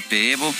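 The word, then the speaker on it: Pääbo: Es, el, sueco, es un investigador sueco, trabaja en Alemania, se llama Svante Peevo se escribe Paavo con doble A y diéresis en las dos, se pronuncia Peevo.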